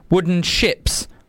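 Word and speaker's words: Wooden Shjips: Today, I've got something just to sort of take you away on a bit of real dreamy psychedelia by a band called Wooden Ships, which has got a silent J in it, S-H-J-I-P-S, Wooden Ships.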